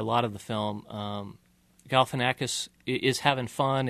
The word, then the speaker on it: Galifianakis: I think that's the way most people pronounce it, although there's an extra I in there, so I think it's Zach Galifianakis.